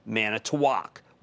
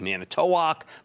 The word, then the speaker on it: Manitowoc: We're going to talk about a company called Manitowoc, which also looks like Manitowoc, but is pronounced the way I did.